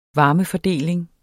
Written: varmefordeling

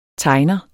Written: tegner

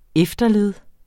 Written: efterled

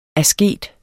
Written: asket

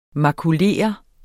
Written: makulere

